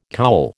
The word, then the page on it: cowl: American English